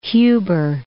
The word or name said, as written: Huber Heights